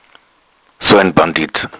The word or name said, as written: bandit